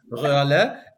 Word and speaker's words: Röhrle: You have pronounced my surname very well in German, Röhrle.